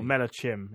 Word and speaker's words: Malachim: Malakim. – It's kind of like the Vin Diesel movie. – Or Malachim, if you want to pronounce it right, but anyway.